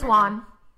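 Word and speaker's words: Wone: It's Wan. It's pronounced. Sorry, it is pronounced Wan because he's Wan.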